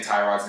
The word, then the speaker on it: Tyrod: Also, they talked about how we've all been saying Tyrod's name wrong. His name is actually Tyrod Taylor. – I guess when he was in college, Lee Corso called him Tyrod, and ever since then, it's kind of just stuck.